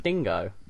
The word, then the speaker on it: dingo: Not Dang. It's not Dango. Why are you saying Dango? – That's how they say it down there. – It's not. It's Dingo.